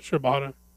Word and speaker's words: Shibata: Shibata.